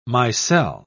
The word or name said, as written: micelle